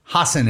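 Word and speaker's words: Hasan: Hassan. Hassan is how you say his name.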